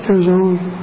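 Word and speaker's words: Girzone: Father Joseph Kerzon, how do we pronounce his name? Kerzon.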